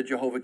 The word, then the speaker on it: Jehovah: So instead of saying the consonants, it came out as it would have been like, I want to say Yehoah would have been the sounding of it when you look at it as just trying to pronounce it. But then you put the J in instead of the Y on the front end, and it became Jehovah. That's where the Jehovah came from.